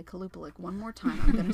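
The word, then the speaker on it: Qalupalik: It wasn't the Kupalik of Alaska. Did I say that right? – No. – Oh. Yes, I did. – Kalupalik. – Oh, Kalupalik. Oops.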